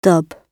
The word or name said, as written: dub